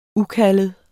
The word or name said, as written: ukaldet